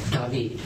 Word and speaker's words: David: I-M-M-A-N-U-E-L. – Your middle name? – David. D-A-V-I-D.